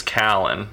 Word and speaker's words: Callen: Name is Callan. It's spelled C-A-L-L-E-N.